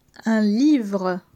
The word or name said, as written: Un livre